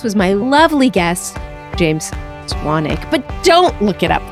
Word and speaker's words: Swanwick: Is it Swanwick? – It's pronounced Swanick.